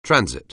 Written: transit

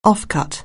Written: offcut

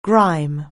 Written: grime

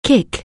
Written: kick

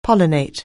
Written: pollinate

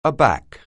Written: aback

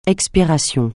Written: expiration

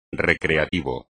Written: recreativo

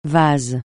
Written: vase

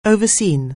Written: oversee

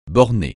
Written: borner

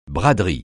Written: braderie

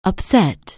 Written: upset

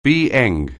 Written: BEng

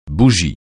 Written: bougie

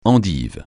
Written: endive

